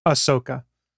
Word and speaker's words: Ahsoka: Is Ahsoka, or how do you pronounce it? – Ahsoka.